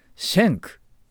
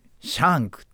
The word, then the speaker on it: Schenk: 彼の名前はねシェンクですけどね綴りも SCHENK シェンクという発音なんですけどほとんどねシャンクって言われちゃうわけですね。で厳密には全然違う発音